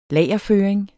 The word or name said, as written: lagerføring